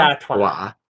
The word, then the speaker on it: Artois: Stella Artois. – Stella Artois. – I don't think you pronounce the R.